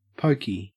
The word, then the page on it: pokey: Australian English